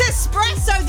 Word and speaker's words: espresso: Kathleen's been in touch and said, when I hear people pronounce espresso, espresso, I don't know why, but it irritates me massively. Kathleen, I'm so with you. It's espresso. There's no X in it.